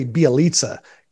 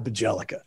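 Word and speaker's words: Bjelica: Like even his other trade for, I had to learn how to say Bielitsa. It's spelled Bajelica. And I was like, it's definitely not Bajelica.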